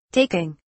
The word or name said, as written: taking